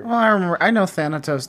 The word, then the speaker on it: Thanatos: Thanatos. That's how you say it.